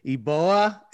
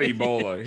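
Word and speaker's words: Eboua: but I believe it's Paul Eboa, and he's an athletic 6'8 forward. – Did you say Ebola? It is not Ebola. His last name is not E-B-O.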